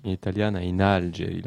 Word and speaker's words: Italiana in Algeri: Non, in Algeri, Camille. Italiana in Algeri, l'accent tonique sur la première.